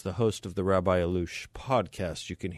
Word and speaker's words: Allouche: Allouche is how he pronounces his name.